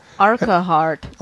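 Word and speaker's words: Urquhart: Which is spelled U-R-Q-U. – We live in the middle of that conversation. – A-R-T. – Yeah. It. – Arkahart. – Arkahart.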